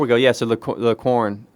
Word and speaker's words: Quorn: First of all, how do you pronounce it? Because every time I say corn, I think of... – It was same. – The band. – Corn. – It's spelled Q-U-O-R-N, right? – Yeah.